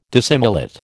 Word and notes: dissimilate: American English

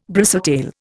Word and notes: bristletail: Indian

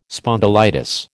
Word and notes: spondylitis: American English

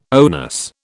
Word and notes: onus: British English

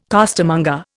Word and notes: costermonger: Indian

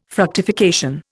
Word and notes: fructification: Indian

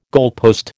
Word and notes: goalpost: American English

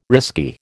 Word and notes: risky: American English

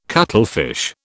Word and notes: cuttlefish: British English

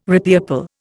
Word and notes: repeople: Indian

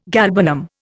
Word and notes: galbanum: Indian

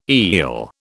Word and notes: eel: British English

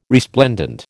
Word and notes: resplendent: American English

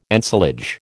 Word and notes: ensilage: American English

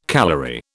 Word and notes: calory: British English

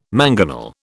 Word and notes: mangonel: British English

